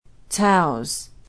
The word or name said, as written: taus